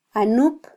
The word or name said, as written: anup